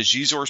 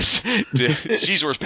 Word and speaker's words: Xizor: So Luke, Lando Calrissian, and Dash Rendar sneak into Xizor's palace to... See, I just said it wrong. Sneak into Xizor's palace to rescue Leia, which leads to a massive climax above the skies of Coruscant.